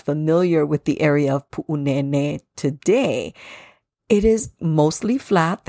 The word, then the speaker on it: Pu'unene: Now, this place often gets mispronounced as Punene. However, there is an okina there, a glottal stop in Pu'u. And there are two kahako, two makrons over the letter E in Nene. So we want to elongate those vowels. So the correct pronunciation is Pu'unene.